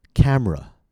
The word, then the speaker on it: camera: How about the word camera? Camera. Notice the 2 reduced vowels. 2nd and 3rd syllables. Camera. A common mistake might be camera. Camera. But it's not ra. A native speaker, an American, would say r. Camera. How about the word Washington?